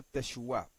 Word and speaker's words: Teshuah: Y se escribe Teshuah, o sea, se pronuncia Teshuah. Es una tau con una sheva debajo, una shin, una wau con un puntito dentro que indica que es una u, y una ayin con su, ¿verdad?